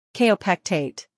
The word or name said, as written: Kaopectate